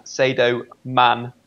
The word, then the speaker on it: Sadio Mané: Or the main man, Sadio Mané. – Wow, look at that pronunciation. Absolutely beautiful.